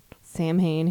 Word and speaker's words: Samhain: So we just had a little uncoven gathering for the Halloweens or Samhain or... Samhain, if you don't know how to pronounce Celtic words,